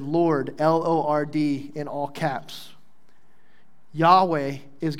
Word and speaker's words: Yahweh: In Hebrew, the name for the Lord had four letters, YHWH. And may have been pronounced something like Yahweh.